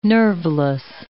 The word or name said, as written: nerveless